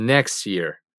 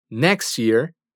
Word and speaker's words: next year: Y next year quiere decir próximo o siguiente año. Checa cómo la pronunciación de las palabras next y year juntas es como si fueran una sola. Next year.